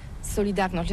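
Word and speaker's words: Solidarność: Alors solidarité, en français on dit solidarnosc, mais ici à Gdansk on ne prononce pas solidarnosc.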